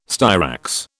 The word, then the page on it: styrax: British English